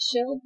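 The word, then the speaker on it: Schild: And when did Dr. Rudy, is it Schill? – Yeah, I'm going to pronounce like an American.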